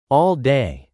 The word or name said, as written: all day